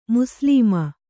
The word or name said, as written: ಮುಸ್ಲಮ